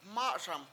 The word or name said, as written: MA7Sam